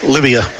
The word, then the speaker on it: Lyvia: Livia. – Oh, Livia. That's an odd way to spell it. – L-Y-V-I-A.